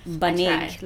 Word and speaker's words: banig: It's Banig. Like, it's got to be a hard G at the end.